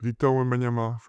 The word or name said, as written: Victor Wembanyama